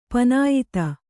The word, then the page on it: ಪನಾಯಿತ: panāyita